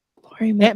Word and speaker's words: Laurie Metcalf: I think I would probably cast, I hope I'm saying her name right, Laurie Metcalf. – Laurie Metcalf? – Yes. – Laurie Metcalf.